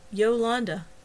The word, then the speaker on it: Yolanda: "Yolanda, did I say it right?" He peered at Travis in the dim light. "You got it." "Yolanda, that's nice."